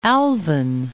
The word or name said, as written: Alvin